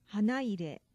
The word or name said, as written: hanaire